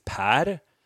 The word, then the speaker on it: Per: Because Per is a Swedish name, and Americans have such a hard time roll their R's. So in Swedish, it's Per, and if you don't speak Spanish, it's very, very difficult for Americans to say my name.